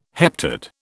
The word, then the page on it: heptad: British English